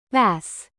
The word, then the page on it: bass: American English; noun